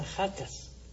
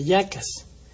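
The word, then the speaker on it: Jackass: Hay una maravilla de serie que se llama Jacas, creo que se pronuncia Yacas,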